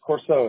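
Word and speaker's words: Corsaut: It's Corso. It's spelled C-O-R, S as in Sam, A-U-T.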